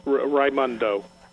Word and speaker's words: Raimondo: Secondly, they cited one of Biden's picks, Gina, is it Raimondo? Am I pronouncing that correctly? – Yeah, Raimondo? Raimondo.